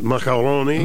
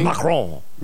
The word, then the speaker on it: Macron: And Macron. – Macron. – His wife and. – Is it macaroni? – Macron. – Macron.